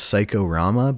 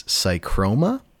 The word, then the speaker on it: Psychroma: I also played, and I kept pronouncing this Psychorama, but it's not. It's Psychroma. It's from Rocket. – There's no O. Like, there's no... – I see Psycho. I see P-S-Y-C-H, and my brain's just like, oh, it's Psycho.